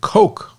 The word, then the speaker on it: Koch: Now, the reason why I'm saying I'm hoping I'm pronouncing it right, because there's a fertility company, an agricultural company, and their name is spelt the same way, K-O-C-H, but they pronounce it Coke, like Coca-Cola, Coke.